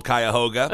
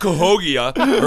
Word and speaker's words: Cuyahoga: It's called Cuyahoga. And I was going to be like, no, it's not Cahogia or whatever.